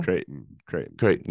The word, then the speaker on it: Creighton: Creighton.